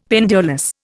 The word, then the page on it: pendulous: Indian